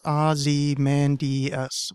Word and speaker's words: Ozymandias: But the meter of it or whatever, Ozymandias shouldn't be pronounced like that, even though that's the only way I know how to say his name. It should be four syllables, and I have it as Ozymandias, which is five syllables.